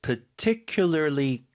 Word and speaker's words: particularly: 프. 첫 번째 발음 파가 아니라 그냥 particularly. 한번 더.